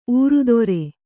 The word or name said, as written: ಊರುದೊರೆ